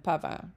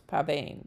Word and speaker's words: pavane: Branle is B-R-A-N-L-E. I had to double-check the pronunciation of branle and pavin, because I always thought it was pavane, but according to what I found, it's pavin, which does sound more French, doesn't it?